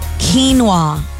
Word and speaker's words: quinoa: Quinoa. Quinoa. Two syllables. Quechua origin from the Andes Mountains.